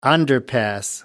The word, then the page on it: underpass: American English